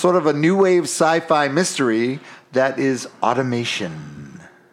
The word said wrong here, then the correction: automaton: Automaton, that's actually, I said automation because I'm an idiot and I've been drinking, but it's automaton.